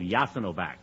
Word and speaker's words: Jasenovac: The largest of the Ustashi concentration camps was a camp called Jasenovac. That's spelled J-A-S-E-N-O-V-A-C.